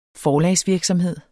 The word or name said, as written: forlagsvirksomhed